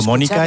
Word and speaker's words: Mónica: Pero no podía dejar de Pero Mónica es esdrújula y entonces aquí lo pronuncian como si fuera aguda.